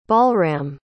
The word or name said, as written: balram